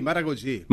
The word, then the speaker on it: Maragogi: En Brasil, Maragogi. – Mira, usted quedó, mira el portugués. Mira, mira, mira, mira. – Mira qué hermoso. – Está bien pronunciado, ¿no? ¿Maragogi? – Sí, Maragogi.